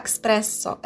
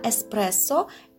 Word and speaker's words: эспрессо: Ни в коем случае нельзя это слово произносить с буквой «к» после «э», да, «экспрессо». Это неправильно, это такого слова нет – в русском языке, и это грубое нарушение норм литературного языка,